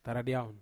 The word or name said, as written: taradiaun